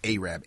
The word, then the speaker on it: Arab: And that's how they pronounced it, Arab, A-R-A-B, where a black family was being harassed by the Ku Klux Klan.